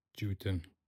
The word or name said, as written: cutin